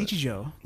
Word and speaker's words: Nichijou: How do you pronounce it? – Nichijou. – Nichijou. I have Nichijou to read. I thought it was Jiao.